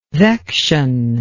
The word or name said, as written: vection